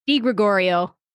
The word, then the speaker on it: Di Gregorio: How do you say it, though? Just, I don't want to butcher it, so. – Di Gregorio. – Okay. That's not that tough. Was it Italian? – It's Italian. You wouldn't think it's that hard. It's just, Italian is easy. It's just like it sounds, but people still butcher it.